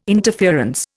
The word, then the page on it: interference: Indian